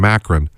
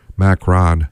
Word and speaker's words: Macron: American pronunciation, Macron. Macron's in power. Macron. You want to say it in French, it's Macron.